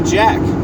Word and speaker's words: Jak: started writing my name with J-A-K instead of taking the C out is because when you pronounce it, it's actually Jack, J-A-K. The C is silent.